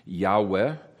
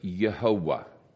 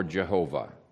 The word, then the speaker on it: Jehovah: Some people pronounce this as Yahweh. Very, very commonly you will see that. If the vowel points are inspired, as I think it is, then you would pronounce this Yehovah or Jehovah. Either one is a legitimate pronunciation of that. And there's three syllables. If you look even of how God forms the foundation for many of people's names, it's one of the proofs that there's three syllables, not two.